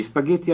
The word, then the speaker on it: spaghetti: Per cominciare, io vorrei gli spaghetti alle vongole. – Repeat. – Gli spaghetti. Gli spaghetti. – Be sure to pronounce Spaghetti. Spaghetti. – with two T's.